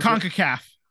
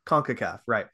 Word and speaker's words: CONCACAF: So, you know, Team USA was playing some, I say friendlies, but you know, technically they were playing to get into the, CONCACAF, I think is how we pronounce CONCACAF. – It. CONCACAF. Right.